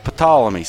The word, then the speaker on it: Ptolemy: Uh, but I, I wrote silent P after his name in parentheses, and I said, all right, Ptolemy, say it with me, Ptolemy. And like people like, huh? Ptolemy, and they'll go, Ptolemy. And I'll say, no, the P silent.